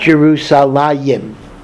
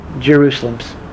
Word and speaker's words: Jerusalem: In fact, that is why the English pronunciation is Jerusalem. It is not Jerusalayim. Jerusalem. Because in fact, the spelling in Tanakh does not have the second yud.